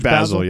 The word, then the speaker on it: Basel: And he's like, yeah, we're going down to Art Basel. Is that how it's pronounced? – Art George Basel, yep.